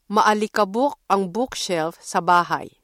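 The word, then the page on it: bookshelf: Filipino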